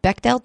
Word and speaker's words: Bechdel: They weren't passing the, how do you pronounce it, Bechdel test? – Yes.